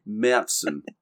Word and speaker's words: Märzen: And I am going to pronounce this correctly. – It shall not be named. – For possibly the first time on the Hop Edition. It's pronounced Märzen.